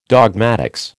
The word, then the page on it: dogmatics: American English